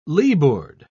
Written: leeboard

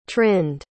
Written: trind

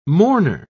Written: mourner

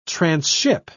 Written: transship

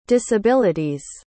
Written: disability's

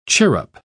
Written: chirrup

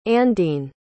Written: andine

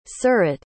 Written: surat

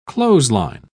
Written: clothesline

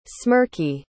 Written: smirky

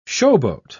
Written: showboat

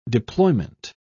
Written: deployment

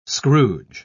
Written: scrooge

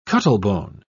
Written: cuttlebone

